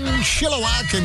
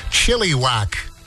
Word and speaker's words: Chilliwack: Jax, 97.7, Chilliwack and Crazy Talk. I had a coworker once who pronounced the name of that band Chilliwack. And I said, hey, dude, by the way, it's actually Chilliwack.